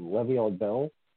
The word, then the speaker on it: Le'Veon Bell: So, which is, I just realized that the Jets kept him because uh, the Chiefs just picked on, I think I'm going to probably butcher this name, Le'Veon Bell. – That's right. That's you, you said it right.